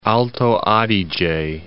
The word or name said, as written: Alto Adige